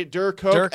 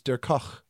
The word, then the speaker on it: Dirk Koch: If it's German, it's Dirk Koch.